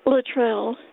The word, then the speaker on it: Luttrell: Luttrell, L-U-T-T-R-E-L-L.